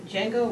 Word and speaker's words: Django: It's by Django Wexler, and it's spelled just like the movie, so it's got the D in front of it. It's like the D is silent.